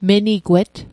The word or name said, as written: menigwet